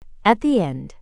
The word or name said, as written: at the end